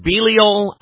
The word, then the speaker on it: Belial: So there's a phrase all throughout Scripture, a name, Belial, B-E-L-I-A-L. It has the name of Baal in it, in the form of B-E-L, Bel. Some pronounce it Belial, I say Belial. You say tomato, I say tomato.